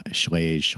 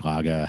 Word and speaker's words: Schlage: And it's basically you take a key that's cut in a certain way for a particular lock, so say a Kwikset or a Schlage, Schlage, however you want to pronounce it, depending on which country you're in.